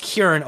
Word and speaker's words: Ciarán: It's pronounced Kieran, although it looks like Ciarán, but because he's Northern Irish, I believe it's pronounced Kieran.